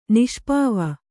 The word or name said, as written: ನಿಷ್ಪಾವ